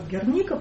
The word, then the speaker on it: Герника: Мы говорили о том, что в России сложилась традиция Герники, просто потому что это 30-е годы, когда не у кого было спросить, как правильно. Это топоним, это город Герника, поэтому повода менять ударение тут нет никакого.